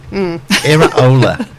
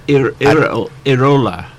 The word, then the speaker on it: Iraola: Iraola. – What? – Iraola, that's a second name. – Iraola.